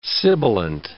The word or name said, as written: sibilant